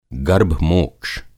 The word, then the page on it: गर्भमोक्ष: Hindi